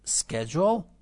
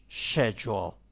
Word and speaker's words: schedule: در بعضی بیشتر جاهای انگلستان این کلمه schedule تلفظ میشه. پس اگر به جای schedule شنیدید schedule، تعجب نکنید، چون American schedule، British schedule.